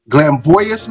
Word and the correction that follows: Glambitious: Magazine, am I saying that right? – Glambitious. Glambitious Magazine.